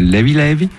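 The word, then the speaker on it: Leve Leve: Alors volume 2 donc de Levé Levé, qui se prononce là-bas Levi Levi, ça veut dire léger léger, tranquillou un peu, c'est, en gros il n'y a pas de stress.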